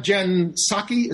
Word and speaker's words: Psaki: Well, we have Jen Psaki.